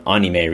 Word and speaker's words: anime: I've been drawing some anime recently,